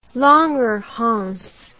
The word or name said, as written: Langerhans